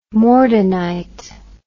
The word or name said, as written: mordenite